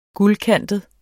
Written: guldkantet

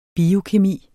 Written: biokemi

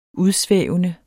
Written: udsvævende

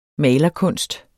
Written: malerkunst

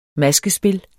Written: maskespil

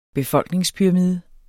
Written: befolkningspyramide

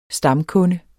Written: stamkunde

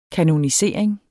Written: kanonisering

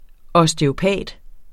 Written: osteopat

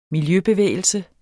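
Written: miljøbevægelse

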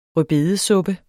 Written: rødbedesuppe

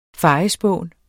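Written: fejespån

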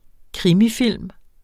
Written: krimifilm

